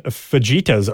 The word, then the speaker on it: fajitas: And I purposely pronounced that wrong. She does have the pronunciation here because I know that we usually pronounce them wrong. Fajitas.